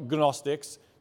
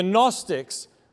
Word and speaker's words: Gnostics: Now, there's a silent G in there, so it's not Gnostics, just like your gnocchi is not gnocchi, but it is delicious. The Gnostics